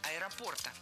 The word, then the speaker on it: аэропорта: И организовали бесплатную доставку этих птиц с аэропорта Москва, московского аэропорта до аэропорта Абакана. – Было сказано «с аэропорта». Радует, что ударение в существительном поставлено верно – «аэропорта».